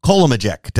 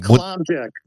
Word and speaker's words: Kolomyjec: Dr. Bill Kolomyjec. Did I say that right, Dr. Bill? – That was pretty darn close. – All right. Kolomyjec.